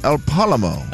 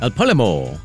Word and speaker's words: El Palomo: A dog named El Palmo. Palomo. El Palomo. – El Palomo.